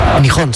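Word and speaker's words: Nihon (日本): ¿Cómo se dice Japón en japonés? – Nihon se dice. – Nihon. – Nihon o Nippon. Están las dos maneras de decirlo.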